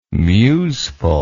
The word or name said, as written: museful